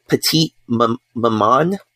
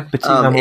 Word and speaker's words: Petite Maman: Petite Maman, I think is how you say it. – Yeah. – Petite Maman.